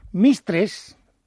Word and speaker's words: mistress: Mistress. Pronunciado mistress, siendo la E una mezcla entre E y A, se utiliza para designar al amante.